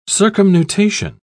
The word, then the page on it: circumnutation: British English